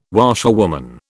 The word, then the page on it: washerwoman: British English